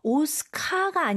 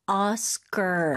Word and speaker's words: Oscar: 끝까지 R 발음 강하게 해주셔야 돼요. 그래서 오스카가 아니고 Oscar.